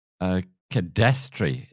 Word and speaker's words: cadastre: And I guess that leads us very, very well into the whole cadastre. Is that the way you pronounce it? – Cadastre, yes. Oh, that word.